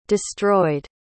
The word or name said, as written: destroyed